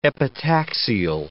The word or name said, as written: epitaxial